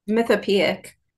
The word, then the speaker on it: Mythopoeic: Mythopoeic? – Mythopoeic. – I was totally not even close. – Yeah, I only learned about it when, you know, I got that recognition. – For the Mythopoeic Award,